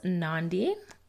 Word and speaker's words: Nandi: I am your host, Nandi, rhymes with Gandhi, life coach.